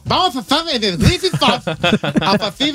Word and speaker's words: Griffith: Cada vez que ponga la TH como the, como THE. – A ver, Griffith. ¿Cómo se dice, pues? – Griffith.